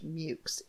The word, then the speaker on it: Meux: It is spelled M-E-U-X, but everything I read and I checked everywhere, and it says that it is pronounced Mukes, M-E-W-K-S, phonetically. – Like pukes? – Only with an M, yes. – With an M.